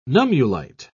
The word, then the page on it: nummulite: British English